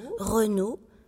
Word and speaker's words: Renault: A-U-T. Comme se prononce O. Renault. R-E-N-A-U-L.